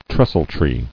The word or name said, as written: Trestletree